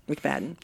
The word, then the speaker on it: McFadden: Her name was Bonnie Bess McFadden. I'm not sure how you want to pronounce that. M-C, capital F-A-D-D-E-N. – I'm going to say McFadden. – McFadden.